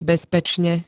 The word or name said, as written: bezpečne